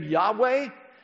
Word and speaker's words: Yahweh: It's a name we really aren't sure how to pronounce. Closest we can come to is the name Yahweh.